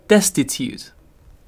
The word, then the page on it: destitute: British English